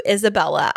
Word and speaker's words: Isabella: It's Isabella, not Isabelle.